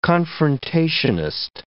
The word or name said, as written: confrontationist